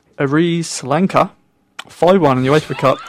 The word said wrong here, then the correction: Aris Salonika: Aris Salonika.